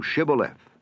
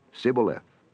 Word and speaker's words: Shibboleth: If he said, Nay, then said they unto him, Say now Shibboleth. And he said, Sibboleth, for he could not frame to pronounce it right.